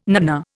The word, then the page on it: nanna: Indian